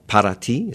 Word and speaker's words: Para Ti: It's called Para Ti, is that how you say it? – Uh-huh.